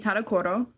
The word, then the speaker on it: Tadokoro: We made a reservation at a one Michelin star sushi restaurant called Sushi Tadokoro. – You did it. – Yeah, I said it right. I kept mixing up the syllables.